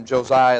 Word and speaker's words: Josiah: You pronounce him Josiah in the Old Testament.